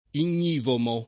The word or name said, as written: ignivomo